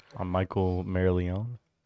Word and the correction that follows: Corleone: Corleone. – Oh, Corleone.